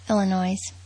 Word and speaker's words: Illinois: Melissa McCarthy was born on August 26, 1969 in Plainfield, Illinois. I think that's how you pronounce it. – Yeah, but it's Illinois. – Oh, what'd I say? – Illinois.